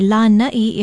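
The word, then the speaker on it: Lana'i (Lānaʻi): Aloha, Lana'i is another major island name that is often mispronounced. People tend to confuse it with the word for a porch or a stiff back chair or a variety of sweet potato. Lana'i is spelled L-A-N-A-Okina'i, and there is a makron or kahako, a stress mark over the first vowel. Say Lana'i if you're talking about the island.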